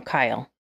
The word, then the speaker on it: Kyle: Oh, Kylie Richards? – No, Kyle. – Is it Kyle? – It's Kyle. – Oh, okay. – Kyle. – How do you spell it? – K-Y-L-E. – Kylie. Kyle? – It's Kyle. Yeah, maybe I should do hold on.